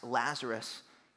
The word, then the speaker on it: Lazarus: I have this blank, but I do remember my sister bagging on me to no end because I could not pronounce Lazarus.